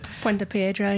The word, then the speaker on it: Puente Piedra: And so I got a chance to go on that mission trip to Peru, Puente Piedra. Is that how you say it? – Puente Piedra, yeah.